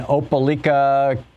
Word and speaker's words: Opelika: Natalie in Opelika, Alabama.